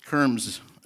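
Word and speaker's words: kermes: Trying to get a, how to pronounce that was really amazing, but Kerms is what I come up with finally.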